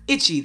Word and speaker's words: Hicce: At Itchy, the place is called Itchy, H-I-C-C-E. Not pronounced the way it's spelled.